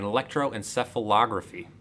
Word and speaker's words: electroencephalography: One of the subjects prior to being locked back into the chamber again is hooked up to an EEG, which is an electroencephalography.